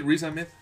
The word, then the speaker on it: Riz Ahmed: A este Rishi, Rishi, ¿cómo se pronunciaba? Ah, Rizame. No, ah, no, es el actor de reparto, lo siento. – Sí, el que sale en Son of Metal. – Sí, sí, Riz Ahmed.